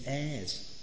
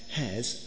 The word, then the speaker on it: heirs: Verse 17 reads, "And if children, then heirs," and heirs, I have to pronounce that correctly. Do you know what I mean? Heirs, as in, you know, a person who basically inherits or who's part of something bigger. Yeah? Not hairs on the head.